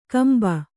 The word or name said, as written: ಕುಂಬೆ